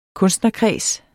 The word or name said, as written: kunstnerkreds